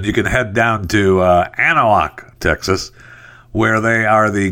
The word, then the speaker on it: Anahuac: So their Texas Gator Fest is happening September 16th, 17th, and 18th in Anahuac, Texas. I think that's how you pronounce it. – Anahuac. – Yeah, okay, Anahuac. Close enough. And at least that's what the computer says. I'm sure one of the Anahuacans will say, that ain't how you say it, boy.